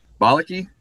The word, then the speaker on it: Bauleke: Am I saying that correctly? – Bauleke? Yep.